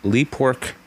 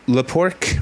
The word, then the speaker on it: Le Pork: And The Luftouts are from the net label Le Pork Records. And that's, or is it Le Pork? And that's how it's spelled, L-E-P-O-R-K.